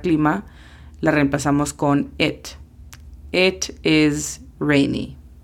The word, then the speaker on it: it: It significa eso o esto. La pronunciación fonética es simplemente E-T, it.